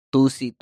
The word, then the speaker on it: tusi: And in Spain, they call it 2C. Tusi. – Yeah, that's why it's spelled T-U-S-I on the thing.